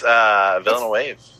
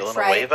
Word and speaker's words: Villeneuve: How do we say his last name? Do you know? – I believe it's Villeneuve. – It's French, Villeneuve, right? I think it's French.